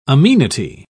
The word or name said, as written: amenity